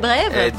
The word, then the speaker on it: brèves: Et les brèves ? – The brave great again. – Brèves. – On peut prononcer le S si on veut.